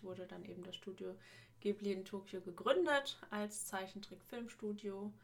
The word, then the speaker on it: Ghibli: Auch das passt dann wieder zu ähm, dem Vater, denn der Name an sich, also Ghibli ausgesprochen, wäre dann eher ähm, Ghibli.